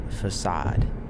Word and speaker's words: facade: The word that you're saying is actually pronounced facade. And she was like, really?